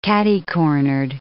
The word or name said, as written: catercornered